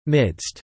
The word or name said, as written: midst